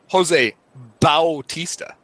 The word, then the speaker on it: Jose Bautista: And then I can't believe in 2015 that Jose, I'm going to say it like the Blue Jays announcers say it, Jose Bautista gets shit for that bat flip.